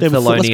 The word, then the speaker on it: Thewlis: I don't know how to pronounce his fucking name. Thewlis? – David Thewlis. Let's go Thewlis.